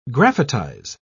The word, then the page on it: graphitize: British English